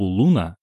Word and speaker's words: Luna: Когда Вит предложил назвать группу «Луна» L-U-N-A, с ударением на первый слог, название всем понравилось.